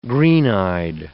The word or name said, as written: green-eyed